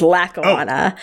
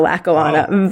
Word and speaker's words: Lackawanna: Also, I gotta say, because this is amazingly kind of like specialty subject for me, it's not Lackawanna Blues, as you pronounce. It's Lackawanna. It's a suburb of Buffalo. I know Lackawanna very well. Lackawanna, New York.